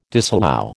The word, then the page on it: disallow: American English